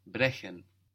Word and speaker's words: brechen: To break is spelled B R E C H E N. So how would you pronounce that? – Brechen. – Brechen. So here we have an E before the CH.